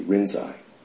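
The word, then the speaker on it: Linji: And the Japanese pronounce Lin Ji Rinzai.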